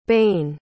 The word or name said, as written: bain